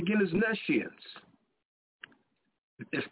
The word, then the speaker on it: nescience: And I'm going to tell you why this word is being ignored in 1 second, but the word is N-E-S-C-I-E-N-C-E. It looks like knee science, but it's pronounced nescience.